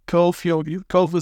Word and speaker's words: Cobh: Cove is spelled COBH. – Yeah, it's like the Americans call it Cob H, but it's COBH.